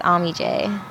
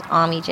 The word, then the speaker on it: AmeeJay: AmeeJay. – Yeah. – That's my name. Just AmeeJay.